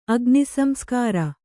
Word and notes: ಅಗ್ನಿಸಂಸ್ಕಾರ: agnisamskāra

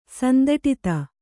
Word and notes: ಸಂದಟಿತ: Kannada

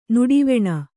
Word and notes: ನಡುವಣ: Kannada

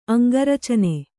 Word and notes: ಅಂಗಾರ್ಚನೆ: aŋgārcane

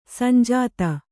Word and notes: ಸಂಜಾತೆ: sanjāte

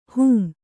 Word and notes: ಹೊಂ: hon/hom

